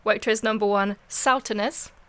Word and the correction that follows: Sauternes: Oh yeah. Saturnus. Oh, oh, it's a Sauternes.